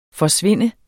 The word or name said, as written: forsvinde